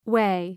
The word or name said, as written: whey